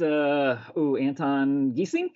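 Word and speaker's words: Geesink: Oh, is that Anton Giesink? – Yes. – Am I pronouncing his name right? That's his name, yes.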